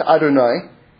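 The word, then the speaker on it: Adonai (אדני): So this name of God, it's pronounced Adonai, but it's spelled Aleph, Dalet, Nun, and Yud.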